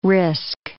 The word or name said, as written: risk